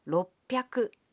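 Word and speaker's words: ropyaku (ろっぴゃく): But the pronunciation can be a bit tricky. 300 is pronounced sanbyaku. 600 becomes ropyaku.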